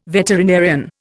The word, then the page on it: veterinarian: Indian